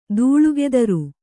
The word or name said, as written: ದೂಳುಗೆದರು